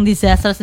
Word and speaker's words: disaster: Dan bacanya tuh bukan disaster. Ada Z-nya dikit ya.